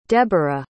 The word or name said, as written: deborah